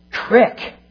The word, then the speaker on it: creek: But Pittsburghers are also betrayed by their indistinguishable pronunciation of the words R-O-L-L, R-O-L-E, and R-U-L-E. That's something I actually practice. I'm getting better, I think. But worst of all, native Pittsburghers pronounce the word creek, crick.